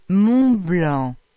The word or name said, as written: Montblanc